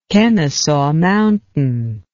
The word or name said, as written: Kennesaw Mountain